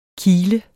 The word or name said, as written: kile